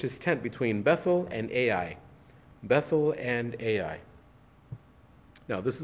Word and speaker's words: Ai: Ai, which is spelled A-I and it's pronounced Ai.